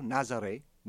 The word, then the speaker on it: Nazaré: His name was Ernesto Nazaré, which was spelled like Nazareth.